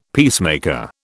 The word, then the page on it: peacemaker: British English